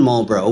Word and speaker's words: Marlboro: I grew up in Marlboro, which did I say that right? – You did.